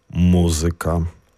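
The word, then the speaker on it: muzyka: Наголос пропарокситонічний, тобто на третій склад від кінця слова, є обов'язковим теж у словах, які закінчуються на «ка». – Математика, музика, логіка, пропарокситоніка.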